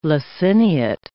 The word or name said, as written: laciniate